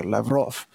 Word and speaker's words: Lavrov: Let me read for you something that President Xi of China just said in a recent meeting with Sergei Lavrov of Russia, trying to get the pronunciation correct there, Lavrov.